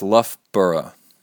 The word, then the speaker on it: Loughborough: Best lit wishes from Loughborough, and it's pronounced Loughborough. Loughborough.